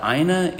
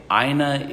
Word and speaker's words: aina: And how do you pronounce it? – A-I-N-A? – Mm-hmm. – Aina. – Okay. – Aina, right? Each vowel gets its own pronunciation in Hawaii, but some of them do blend.